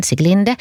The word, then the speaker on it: Siglinde: Och så har vi då Sikli, eller Siglinde, hur den uttalas, Siglinde.